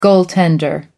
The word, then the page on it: goaltender: American English